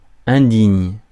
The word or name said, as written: disgraceful